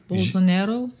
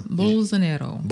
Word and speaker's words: Bolsonaro: Bolsonaro? – Yeah. – Bolsonaro.